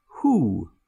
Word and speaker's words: who: The W is also unpronounced or silent in a small number of words where the next letter is H. For example, who, who's there?